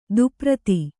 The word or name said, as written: ಧೂಪಾರತಿ